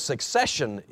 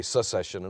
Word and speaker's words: succession: The issue of succession in Rome, did I say that right? Succession, yes. I always say secession, and they're so close, and they mean so different things.